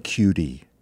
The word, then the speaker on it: Cudi: It features Billie Eilish, Her, and Kid Cudi, which I'm told is the correct pronunciation of Cudi. – I just learned C-U-D-I.